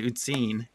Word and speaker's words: Uzin: Uzine. Did I pronounce that right? – The Germans pronounce it Uzine. So Uzine.